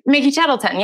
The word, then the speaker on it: Mickey Tettleton: Mickey Tettleton.